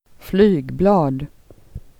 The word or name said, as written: flyg